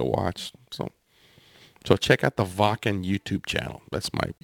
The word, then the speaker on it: Wacken: Yeah, because in German, the W is pronounced like a V. So yeah. It would be Wacken to you wacky Americans, but it's pronounced Wacken. – W-A-C-K-E-N.